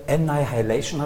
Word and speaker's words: Annihilation: Unsere Filme lauten heute Annihilation, habe ich mir sagen lassen. So wird das ausgesprochen.